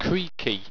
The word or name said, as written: kreakcea